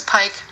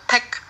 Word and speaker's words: Paik: Äh, allerdings spricht man den nicht Paik aus, sondern Paik. und jetzt habe ich auch mal das Hangul, also auf Koreanisch geschrieben und es ist halt wirklich ein E-Sound, eigentlich sein Paik.